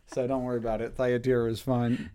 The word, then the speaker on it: Thyatira: I can do it, but how do you say that name? Thyatira? – I say Thyatira. – Thyatira? – Yes. – You would know more than me. – Yeah, I mean, a lot of these biblical names, like if you pronounce them like they were actually written in the biblical languages, you'd sound like a crazy person. So I think Thyatira is fine.